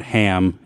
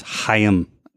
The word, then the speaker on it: Chaim: And then apparently too, it's not Ham. It's Chaim.